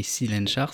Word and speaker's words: Cylenchar: qui s'appelait Colencar. Ça s'écrit C-Y-L-E-N-C-H-A-R. Donc, ça s'écrit Silenchar, si tu veux.